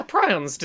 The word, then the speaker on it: prion: Well, we talked about this earlier, and the audience was like, it's pronounced prion. And I was like, damn, never heard it out loud before.